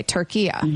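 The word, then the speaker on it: Türkiye: Okay, Turkiya. – There you go. That's perfect. That's how it's pronounced. It's always been pronounced that in Arabic language. – Okay. – It's a short A at the end, not the long A.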